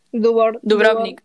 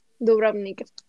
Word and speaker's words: Dubrovnik: Dubrovnik. Ah. No, en Croacia. Dubrovnik.